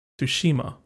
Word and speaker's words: Tsushima: Ghost of Tsushima. Is that how you pronounce it? Do you pronounce the T? – Tsushima. – Tsushima. – I think you do.